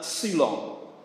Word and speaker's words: xulon: It's spelled X-U-L-O-N. And I'm not very good at my pronunciation. It's probably xulon, something like that.